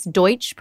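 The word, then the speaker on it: Deutch: That's Deutsch, pronounced D-O-Y-T-C-H, not to be confused with douche.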